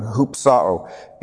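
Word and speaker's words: hupsao (ὑψόω): Lifted up again, hupasso, means to be crucified, but also it means to be glorified and exalted. I pronounced that wrong, hupsao.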